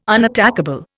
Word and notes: unattackable: Indian

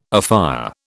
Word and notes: afire: British English

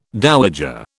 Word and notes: dowager: British English

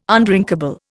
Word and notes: undrinkable: Indian

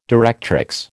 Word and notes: directrix: American English

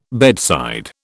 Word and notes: bedside: British English

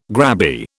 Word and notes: grabby: British English

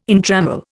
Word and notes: entrammel: Indian

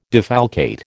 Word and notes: defalcate: American English

